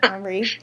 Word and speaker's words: Henri: Grand Duke Henry, I'm terrible with the French pronunciation, Henri.